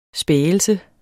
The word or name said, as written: spægelse